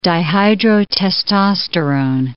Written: dihydrotestosterone

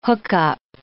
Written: hookup

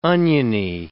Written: oniony

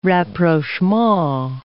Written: rapprochement

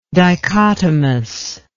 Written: dichotomousness

